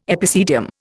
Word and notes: epicedium: Indian

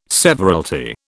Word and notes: severalty: British English

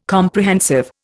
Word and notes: Comprehensive: Indian

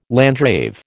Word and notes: landgrave: American English

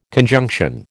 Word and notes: conjunction: American English

